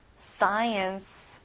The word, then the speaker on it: science: Science. 앞에 SC로 돼 있지만 K 발음은 안 나요.